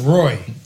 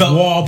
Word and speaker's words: Roy: Yeah, I thought it was Roy. – It's spelled Roy, but it's Waugh because it's Montreal Canadiens. – Okay, it's the French. It's the French pronunciation.